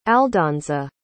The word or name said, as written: aldonza